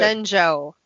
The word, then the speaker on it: Shenzhou: Here, Burnham is the former captain of the ISS Shenzhou. Did I say that right? – You did. – Shenzhou.